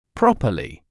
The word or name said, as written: properly